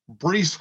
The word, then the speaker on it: Breece: Bryce Hall. Breece Hall. So I said Breece's name wrong.